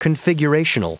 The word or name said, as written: configurational